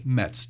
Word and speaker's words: Metz: Metz, just like the baseball team.